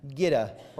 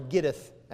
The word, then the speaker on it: Gittith: Verse 1, to the chief musician upon Gitta, or Gittith as it's pronounced.